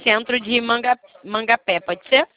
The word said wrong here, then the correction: Mamanguape: é de Mamanguape.